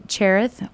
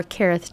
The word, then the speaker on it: Cherith: He goes to Cherith or Kereth, depending on your translation on how it's spelled. Sometimes it's spelled with the CH, sometimes it's spelled with a KE.